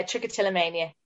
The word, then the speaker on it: Trichotillomania: Trichotillomania. – Yeah, Trichotillomania.